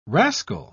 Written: rascal

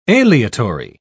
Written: aleatory

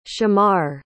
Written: shamar

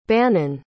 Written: bannan

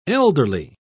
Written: elderly

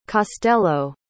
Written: costello

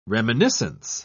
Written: reminiscence

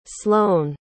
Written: slone

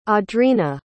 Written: audrina

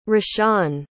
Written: rashawn

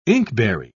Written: inkberry

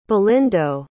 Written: belindo

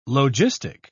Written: logistic

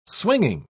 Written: swinging

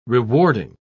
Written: rewarding

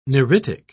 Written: neritic

